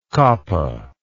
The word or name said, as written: koppa